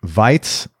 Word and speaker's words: Weiz: So you grew up in, and let's see if I get this correct, Weiz in Austria. Is that how you pronounce it? – That's how I pronounce it, yeah.